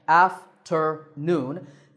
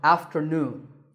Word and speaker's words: afternoon: Three syllables again, afternoon. This time, the third syllable is stressed. So, afternoon, like when we say good afternoon.